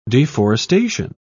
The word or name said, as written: deforestation